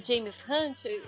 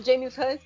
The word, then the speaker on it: James Hunt: Aí eles sempre colocam aquela sequência do filme Rush, que tem o Nick Lauda, ai, o James Hunt, eu falei certo o nome? – Falou o piloto? Certo o James Hunt.